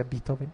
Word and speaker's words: Beethoven: Y después Beethoven, se pronuncia Beethoven, lo hizo más importante.